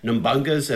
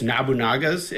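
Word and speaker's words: Nobunaga: As kids, we pronounced it as "Let me see if I know." Nambunga's ambition, but it's Nabunaga's ambition. so,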